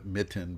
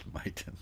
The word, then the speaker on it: Miten: That purity is translated well, and and she connected with a guy named Miten, although I like to call it Mitten because it's just kind of funny for me, you know, guy named Mitten. But anyway, it's it, it's actually pronounced Miten.